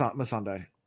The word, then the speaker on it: Missandei: Is it Missandei or is it Melisandei, or I can't remember how it's pronounced. – Missandei. – Missandei, okay. – There's no L, I don't think.